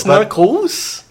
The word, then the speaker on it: Narcos: I like that you pronounce it Narcos. Like, Marcos. – Narcos. It's very American. – Really? Know, it's Narcos? – No, because it's plural Narcos. Of narco.